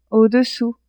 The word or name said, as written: au-dessous